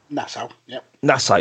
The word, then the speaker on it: Nassau: Evolution here was held at the, and you have to excuse me if I pronounce this wrong, Nassau Coliseum, I believe is what they call it. – Nassau, yeah.